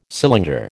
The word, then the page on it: cylinder: American English